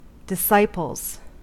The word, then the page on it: disciples: American English